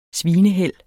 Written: svineheld

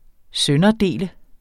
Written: sønderdele

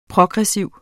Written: progressiv

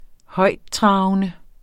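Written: højttravende

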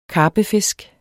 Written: karpefisk

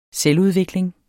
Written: selvudvikling

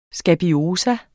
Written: skabiose

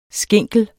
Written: skinkel

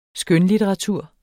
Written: skønlitteratur

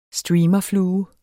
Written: streamerflue

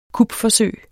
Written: kupforsøg

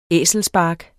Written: æselspark

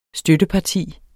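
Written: STØTTEPARTI

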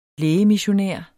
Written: lægemissionær

